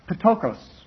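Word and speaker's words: Ptokos (πτωχός): And I was told by one Greek teacher that you always pronounce the P and the T. And others say you don't do that. So no one speaks ancient Greek anymore, so no one really knows how it's supposed to be pronounced. But the way I was taught, you'd pronounce it Ptokos.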